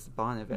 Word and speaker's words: Bon Iver: Or are we just going to say Bon Iver? – Oh, that's what it is. Bon Iver. – That's the band... But his name is, Justin Vernon. Bon Iver is the band that he's in.